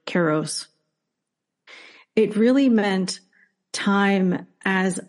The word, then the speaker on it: Kairos: So, and I'm going to pronounce Kairos as Kairos, which is like more the modern Greek pronunciation.